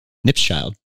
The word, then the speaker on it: Knipschild: I think you pronounce it, it's like cheese nips, but with a child. Nips child.